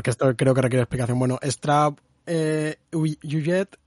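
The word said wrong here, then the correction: Huillet: que se pronuncia así, ah, ¿no? – sí, creo que sí. – Bueno, realmente es Jean-Marie Straub y Daniel Yujet,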